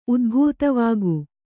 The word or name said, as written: ಉದ್ಭೂತವಾಗು